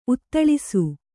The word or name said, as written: ಉತ್ತಳಿಸು